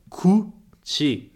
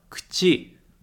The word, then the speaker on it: 口: A gente não fala muito cu-ti, né? Com esse som de K-U muito forte. A gente fala cu-ti. Cu-ti é como se fosse um K mudo, ti.